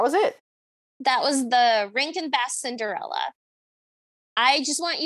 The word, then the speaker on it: Rankin: I keep saying Raskin and Bass, and it's not.